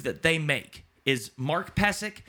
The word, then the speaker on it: Pysyk: It's P-Y-S-Y-K. Oh, no. – Yeah, P-Y-S-Y-K. – Yeah, P-Y-S-Y-K. But it's pronounced Pesek.